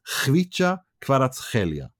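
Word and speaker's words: Khvicha Kvaratskhelia: Yeah, but he pronounces it Hvica Kvaratskhelia. That's him. That's according to him, not me. That's, you know, go to the Napoli English where he there's a video of him saying it.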